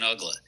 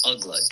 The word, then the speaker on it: Uggla: When the Marlins acquired through the Rule 5 draft, it was amazing how they got this guy, Dan Uggla.